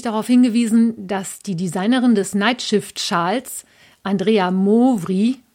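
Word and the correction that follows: Mowry: wie ich sie genannt habe, aus dem amerikanischen oder aus dem US-amerikanischen Raum stammt und daher wahrscheinlich eher Andrea Mauri ausgesprochen wird.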